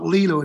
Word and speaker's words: Lilou: I've done about 10 interviews, and it's spelled Lilou. It's L-I-L-O-U. – Right. – And if you type in my last name, which is L-O-S-I-E-R, if you type in Lilou and Loge, all of the stuff we did together.